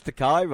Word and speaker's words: Takei: George Takei rhymes with oh my.